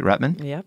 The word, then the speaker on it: Rutman: Did I say it right? Rutman? – Yep.